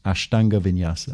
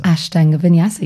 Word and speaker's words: Ashtanga Vinyasa: Ashtanga Vinyasa. – Ashtanga Vinyasa, yes, you did.